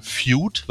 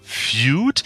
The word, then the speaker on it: Feud: Feud, war richtig ausgesprochen? Korrekt. Feud.